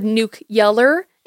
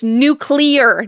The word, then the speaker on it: nuclear: I will say at one point he said nuke yeller instead of nuclear, and that is one of my biggest pet peeves. That's not how it's pronounced. It's not how it's spelled. It's nuclear, not nuclear.